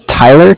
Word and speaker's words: Tyler: My name is Tyler, T-Y-L-E-R, and I am calling from Bank of Cardiff. It's spelled C-A-R-D-I-F-F, as in Frank.